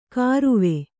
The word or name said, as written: ಖಾರ್ವ